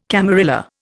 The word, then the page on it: camarilla: Indian